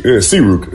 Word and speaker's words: Sayruk: Yeah, Sayruk. – Sayruk, pardon. – Like sea, like an ocean.